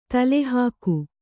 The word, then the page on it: ತಲೆಹೋಕ: talehōka